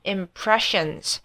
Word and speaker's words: impression: 就是 form 就过去了，把它拉长一点，form 有一个 O 型嘴巴合起来 form lasting impressions 这边提醒大家一下，不要自己举一反三，就把它跟昨天的那个 express 一样，把 p 念成 b 的音哦，他们两个不一样哦 impression 都要念 P 哦，不是像 express 那样可以变成 B 的音。